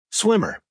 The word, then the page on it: swimmer: American English